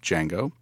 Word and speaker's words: Django: So I will end this with a joke that I thought of when you brought up Django. And the joke is, what is the similarity between Mike D'Antoni's Lakers and Django? – What? – There's a lot of shooting and the D is silent.